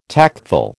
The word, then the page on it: tactful: American English